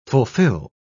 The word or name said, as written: fulfil